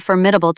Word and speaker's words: formidable: Facing a formidable challenge means you'll need all your grit to overcome it. Stress the first syllable.